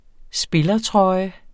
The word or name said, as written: spillertrøje